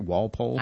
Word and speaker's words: Walpole: Am I pronouncing that right? Walpole? – I believe so.